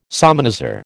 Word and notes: salmanazar: American English

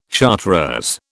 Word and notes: chartreuse: British English

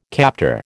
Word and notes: captor: American English

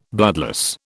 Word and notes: bloodless: British English

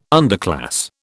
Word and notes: underclass: British English

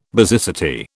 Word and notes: basicity: British English

